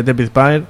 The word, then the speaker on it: David Byrne: Me toca uno de los temas, mis temas fetiches y mis grupos, vamos, básicos, son los Talking Heads, grupo de David Byrne.